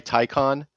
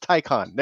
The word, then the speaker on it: Taycan: It's Thai, like you're wearing a tie, and then a con, like a conference for ties. It's a Thai conference, Taycan.